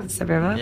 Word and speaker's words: Sevierville: I have to come all the way to Tennessee to go to the Bucky's in Sevierville. Did I say that right? – Mm-hmm. Yeah. – Sevierville? – Yeah.